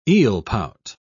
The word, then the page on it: eelpout: British English